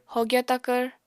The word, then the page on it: hogya takvr: apatani